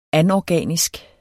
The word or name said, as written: anorganisk